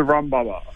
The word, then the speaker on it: baba: How's it pronounced? Baba? – Yeah, that's it. It's a rum baba.